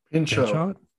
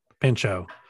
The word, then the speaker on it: Pinchot: And it's uh, played by, and I'm going to butcher his last name, is Bronson Pinchot. – Pinchot. Mm-hmm. – Pinchot.